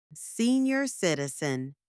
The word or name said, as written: senior citizen